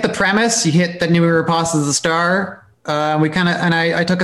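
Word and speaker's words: Noomi Rapace: And she said, it's Namita Rapace.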